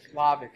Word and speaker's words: Slavik: How do you spell that? Nick Slavik? – Slavik. S-L-A-V-I-K.